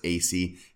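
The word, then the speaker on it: Asay: Now, according to Sister X, her bishop told her that he would get in contact with Carlos Assay, who was a member, or it's AC. It was a little bit of an unusual pronunciation.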